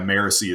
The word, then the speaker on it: Marasi: No, yeah, Marisi is how Brandon pronounces it.